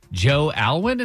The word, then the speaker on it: Joe Alwyn: Number five, Taylor Swift's new beau, Joe Alwyn, is that how you say it, Drex? – That's correct.